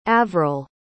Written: avryl

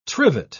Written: trivet

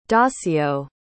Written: dacio

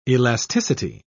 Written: elasticity

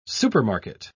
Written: supermarket

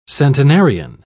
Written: centenarian

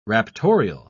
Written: raptorial

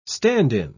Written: stand-in

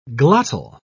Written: glottal

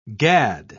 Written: gad

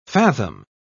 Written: fathom

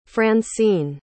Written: francine

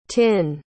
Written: tinh

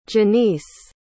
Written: jeniece

